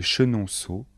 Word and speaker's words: Chenonceau: Les plus célèbres sont les châteaux de Chambord, de Blois, de Chenonceau et le château d'Amboise. Attention de bien prononcer les noms de châteaux. Répétez après moi.